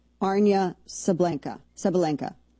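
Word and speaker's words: Aryna Sabalenka: And then you have, and then women's tennis number one, who, how do you say her name? Arina Sabalenka.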